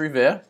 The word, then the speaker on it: Revere: You were a little off. It's Revere.